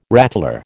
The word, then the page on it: rattler: American English